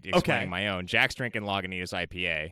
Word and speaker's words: Lagunitas: Listener, I usually call it Lagunitas.